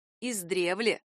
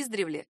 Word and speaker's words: издревле: А тем, кто любит исторические тексты, не помешает знать, что наречие «издревле» произносится с ударением на второй слог — «издревле». Вариант с ударением на приставке «издревле» ошибочен.